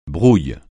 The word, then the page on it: brouille: francais